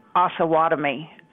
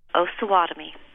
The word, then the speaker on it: Osawatomie: The town that President Obama is speaking in is pronounced Osawatomie. This is Wichita, Kansas.